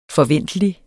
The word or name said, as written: forventelig